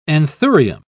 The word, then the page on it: anthurium: British English